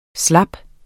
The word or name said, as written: slap